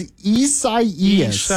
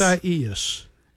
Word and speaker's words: Isaias: No, apparently it's Isaias. – Isaias. – Is how you pronounce it.